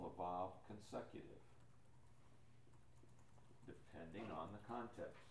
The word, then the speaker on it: vav: The W is pronounced as a V, vav.